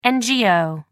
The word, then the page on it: ngo: American English